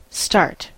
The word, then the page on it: start: American English